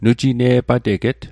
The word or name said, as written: nujine'pateget